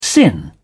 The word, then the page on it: sin: British English